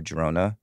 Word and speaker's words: Girona: Girona, am I pronouncing it right? – Yep.